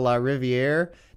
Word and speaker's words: LaRiviere: Anyway, joining me today is Mr. Darrell LaRiviere.